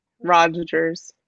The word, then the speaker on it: Rodgers: It's Rodgers with a D. Rodgers. – It's right. The D is silent, like Django. like Django. It's Rodgers.